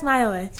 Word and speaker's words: Maiola: Like people would always be like, oh, that's Maiola. She's right there. I'm like, yeah, that's me. – Nice. Maiola, Iowa with an M in front of it.